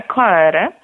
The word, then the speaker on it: Coere: Przyjęło się w zespole, że nazwę wymawiamy tak, jak ty to, Pawle, już zaprezentowałeś, czyli Coere. Mm-hmm. Powtórzę, Coere.